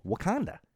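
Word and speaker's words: Wauconda: It is spelled differently, but it's called Wakanda.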